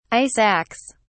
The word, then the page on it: ice axe: British English